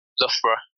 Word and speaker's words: Loughborough: Loughborough.